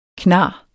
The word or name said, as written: knarr